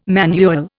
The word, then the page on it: manual: Indian